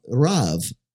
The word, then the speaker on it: Rav: in Hebrew, the language of the Jewish people, the nation of Israel, is uh, Rav. Actually, that's how it's pronounced.